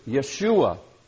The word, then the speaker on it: Yeshua: In Hebrew, it's Yeshua or Yehoshua. Before the Babylonian captivity, it was pronounced Yehoshua. After the Babylonian captivity, I don't know why, it then was pronounced Yeshua.